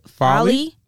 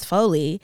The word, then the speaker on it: Folly: I believe it's pronounced, because I call it Folly. – Folly. – But, like, somebody else is like, no, it's Foley.